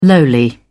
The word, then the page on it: lowly: British English